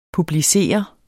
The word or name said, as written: publicere